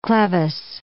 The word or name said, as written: clevis